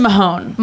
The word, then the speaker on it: Mahone: Austin Mahone. – Mahoney. – Is it Mahoney? – Mahone. – I said it wrong. – I think then. – You're right, Mahone.